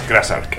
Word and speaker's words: Krazark: Bueno, hablando así de testosterona y este tipo de cosas, antes de pinchar un tema de Krasark, que bueno, perdona, que yo siempre pronuncio Krasark, y estoy aquí. – Como quieras. – Krasark. – A nosotros nos gusta más Krasark. – Krasark, ¿no? Pues Krasark.